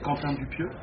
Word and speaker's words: Quentin Dupieux: Is that right? C'est Quentin Dupieux.